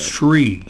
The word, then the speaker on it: Sri: Pardon me, his name is spelled S-R-I? – S-R-I, yes. – Shree.